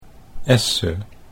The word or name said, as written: Esse